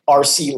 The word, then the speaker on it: Arce: To reach out to me, my handle for everything is Mike RC Live. So my middle name is a tricky one. It's spelled A-R-C-E. So okay. It's just Mike RC Live.